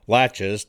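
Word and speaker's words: laches: That's L-A-C-H-E-S. You say it like it has a T in it. Latches. Doctrine of Latches.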